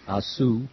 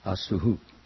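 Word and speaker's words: asuḥ: and some of us are pronouncing Asuhu. So the second is the correct. The rule that I found out was that when you see an H with a dot under it, it takes the sound of the previous vowel.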